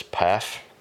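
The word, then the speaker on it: Pfaff: Her bassist, the bass player for Hole, Kristen, I hope I say this right, I believe it's PAFF, it's P-F-A-F-F, died June 16th, 1994.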